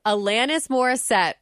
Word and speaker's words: Alanis Morissette: Alanis Morissette.